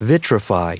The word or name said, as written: vitrify